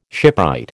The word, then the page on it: shipwright: American English